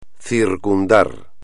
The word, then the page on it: circundar: espagnol